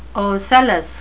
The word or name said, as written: ocellus